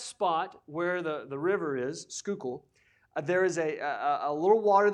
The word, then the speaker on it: Schuylkill: Schuylkill, I think, is how you pronounce it. What was it? Schuylkill. There it is, Schuylkill.